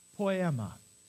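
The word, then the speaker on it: Poema (ποίημα): Here's the Greek word. Pronounced. Poema. Say it. Now think for a sec. What word in English does that sound like? Somebody said it.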